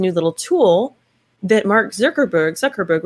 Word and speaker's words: Zuckerberg: Anyway, Mark Zuckerberg, how do you say his name? – Zuckerberg.